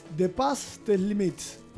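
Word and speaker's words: Dépasse tes limites: Exacto, es De Passé Tes Limites, porque la E no se dice ahí.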